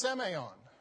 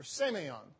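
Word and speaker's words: semeion (σημεῖον): It's pronounced semeion, or semeion.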